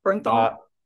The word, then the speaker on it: Bernthal: And it was Eben Moss who got John Bernthal to play the part of. – Absolutely. – How do you say his last name correctly? Bernthal? – Yeah, Bernthal.